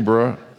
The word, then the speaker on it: bruh: I got my bros, if I'm saying that right, right? Did I say that right? Hey, bruh, say it for me. Bruh, that's what I said, just whatever.